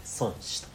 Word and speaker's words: 孫子: えっとね、漢字で孫と書いて、子供と書いて、孫子と読むんですけど。